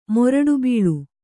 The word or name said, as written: ಮೊರಡುಬೀಳು